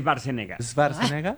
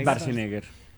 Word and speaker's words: Schwarzenegger: Es Schwarzenegger. Para nosotros nos enseñaron. Es Schwarzenegger.